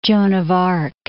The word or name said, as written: Joan of Arc